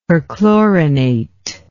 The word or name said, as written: perchlorinate